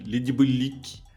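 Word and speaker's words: Les Diaboliques: E analisaremos ao filme francês Le Diabolique. Eu acredito que seja assim a pronúncia. Não... – não, é? você tem que fazer o biquinho. – Le Diabolique.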